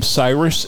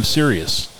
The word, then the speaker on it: Sirius: The day that Howard signed on was the day that the company no longer became Cyrus and became Sirius. Because people knew how to pronounce our name, and they knew what the hell we were, and all that.